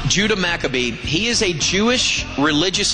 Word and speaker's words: Maccabee: Maccabee, I'm hearing. Maccabee is the way to pronounce this.